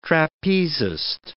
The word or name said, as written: trapezist